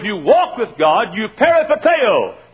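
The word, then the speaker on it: peripateo (περιπατέω): The word walk in the Bible is the word in the Greek peripateo. P-E-R-I-P-A-T-E-O is about the way we pronounce it.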